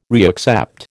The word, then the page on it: reaccept: American English